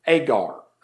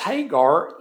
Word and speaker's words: Hagar: Agar. The Old Testament pronunciation is Hagar. For this Hagar is Mount Sinai in Arabia and answereth to Jerusalem, which now is and is in bondage with her children.